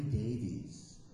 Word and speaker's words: Davies: Because the E in America, you're Davies. You want to be Davis, take the E out.